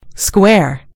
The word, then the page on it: square: British English